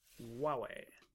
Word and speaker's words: Huawei: My understanding from our Chinese colleague who worked with us last year was that you don't pronounce the H. – Nope. – It's Huawei.